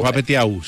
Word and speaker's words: Pape Thiaw: Bueno, pues sí, imagínense que Pape Thiaw, como pueden pronunciarlo, es el seleccionador de Senegal. ¿Sí? – Entonces, claro, Pape Thiaw, sí.